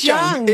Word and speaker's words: Jung: I tell you, in my defence, his name was spelt with a J. – So J-U-N-G. And my little dyslexic brain just went, I don't know what to do with that. – So it's not Chung. I thought it was Chung, but it's actually... – I don't know, it is Chung. – It is Chung. Okay, it's Chung.